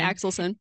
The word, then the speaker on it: Axelson: Axelson. Yeah, you got it. Axelson.